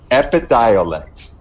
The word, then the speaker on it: Epidiolex: Epilidolex. – Epidiolex. – I was close.